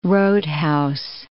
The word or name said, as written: roadhouse